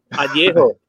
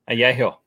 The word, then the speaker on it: Añejo: Añejo. – Añejo.